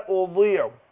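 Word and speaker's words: أضيع: هنا ما اقول لا اضيع عمله، الضاد كتير بينطقوها دال، يقول لا اضيع، اضيع لا، اقول لا اضيع، اضيع.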